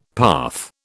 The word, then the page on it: path: British English